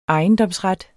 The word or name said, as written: ejendomsret